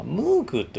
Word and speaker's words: Moog: MOOG と書くので、まあ、ムーグというふうに発音してたんですねだからモーグシンセサイザーのことをムーグシンセサイザーと呼んでたんですよ